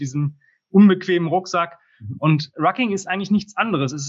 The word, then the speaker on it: Rucking: und zwar was ist Rocking, wenn ich es richtig Rucking. ausspreche? – Rucking.